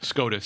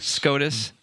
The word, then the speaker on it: Scotus: SCOTUS. – SCOTUS, yep.